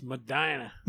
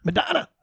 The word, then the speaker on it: Medina: Well, if you're a hillbilly from Ohio, it's Medina. – Medina. – All right, that's what we'll go with. – If you're from Texas, they say Medina.